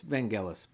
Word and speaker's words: Vangelis: It's Vangelis, but okay.